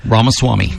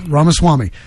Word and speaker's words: Ramaswamy: Ramaswamy. – Ramaswamy.